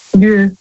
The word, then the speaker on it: Lieu: Lieu. – Lü? – Also Lü, das schreibt man also L-I-E und dann U.